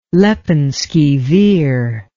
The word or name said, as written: lepenski vir